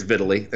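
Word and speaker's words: Vitali: Vitaly, how do you say it? – I think it is Vitaly. – Oh, okay. – I don't know. That's a good question. I'm pretty sure it's Vitaly. I think that's what they've always kind of come back to.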